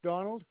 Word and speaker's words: Donald: It's Donald. – Can you spell it for me? – D-O-N-A-L-D.